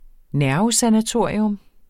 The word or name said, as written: nervesanatorium